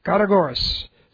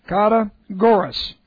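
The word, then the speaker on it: kategoros: Kategoris. So let me put it on the board. I want to show you a few things. Kategoris. That's K-A-T long E and then G-O-R-O-S. Kategoris.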